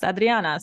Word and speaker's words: Adriana: And I love that you pronounced my name how it was originally pronounced, Adriana.